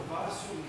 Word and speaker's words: vasu: Vasu, yeah, there in that sentence, the derivation of the word vasu, it's short a.